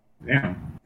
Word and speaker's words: Zam: Uh, Vam. Now, why am I saying her name wrong? – Zam. Zam. Zam. – I was, I was like, Zam. I was like, wait, that's not right.